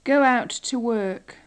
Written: go out to work

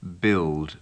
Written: build

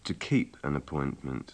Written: to keep an appointment

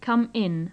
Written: come in

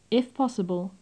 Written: if posible

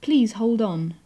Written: please hold on

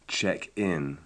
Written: check in

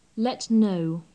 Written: let know

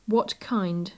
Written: what kind

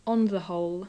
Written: on the whole

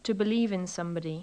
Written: to believe in sb

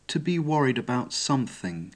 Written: to be worried about sth

